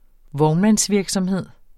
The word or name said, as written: vognmandsvirksomhed